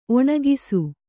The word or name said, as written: ಒಣಗಿಸು